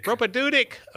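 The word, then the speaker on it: propaedeutic: You know, when we had to go to break, but you were talking that first year of formation, the did I say it right? – Propadeutic. – Okay. – Yes. You were close.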